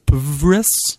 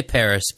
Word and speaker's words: PVRIS: I don't know if you pronounce it Paris or Pvris. – They say Paris, but it's got Paris? The V in it. Yeah. – Okay. I don't know. Paris is putting out White Noise, also on Rise Records.